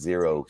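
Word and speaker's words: Xero: It's X-E-R-O. So I'm going to guess it's Zero Shoes.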